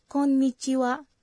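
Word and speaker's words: こんにちは: Hal yang sama berlaku juga bagi wa dalam konnichiwa. – Selamat siang atau halo. Ini diucapkan sebagai wa namun dieja dengan huruf h.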